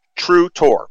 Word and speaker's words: Trutor: I mean, Clayton Trudor, who is, and I want to make sure I pronounce his name right. I want to be respectful. Trutor.